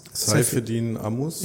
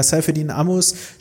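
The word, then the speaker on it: Saifedean Ammous: Saifedean, wie spricht man den aus? – Saifedin Amus. – Ja, Saifedin Amus spricht sich ja für den Bitcoin-Standard statt dem Gold-Standard aus.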